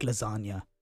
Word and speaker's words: lasagna: So, you know how Lil Wayne said, real G's move in silence like lasagna? – Yeah. – That's a punchline because... – Yeah. – The G is silent in lasagna.